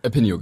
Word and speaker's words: Apinio: Apinio ist schon die erste Frage, ist es wie richtig ausgesprochen? – Apinio, genau. – App und Opinion.